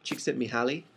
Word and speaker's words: Csikszentmihalyi: It comes from a guy named Csikszentmihalyi. That is actually the right way to pronounce it. – Csikszentmihalyi.